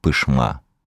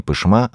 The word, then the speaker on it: Пышма: Своим названием Верхняя Пышма обязана реке. В переводе с татарского Пышма означает «спокойная». Местные жители ревностно следят за правильностью ударения, которое падает на второй слог.